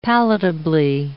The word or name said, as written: palatably